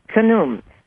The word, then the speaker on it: Khnum: And you talk about Khnum, and correct me if I'm not pronouncing it correctly. – No, that's correct. – Khnum.